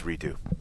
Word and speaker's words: Redux: And then you got the Fail Mary in Redux, Atlanta. And then you got, It's redo. It's the X is silent, sort of like the D is silent in Django.